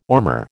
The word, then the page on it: ormer: American English